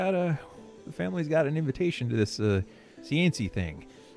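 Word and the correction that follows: séance: Oh, seance! There's a seance here. – Oh, that's how you say it.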